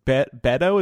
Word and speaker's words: Beto: Laura, as you've been speaking, I clicked this Beto is how you pronounce his name? – Mm-hmm.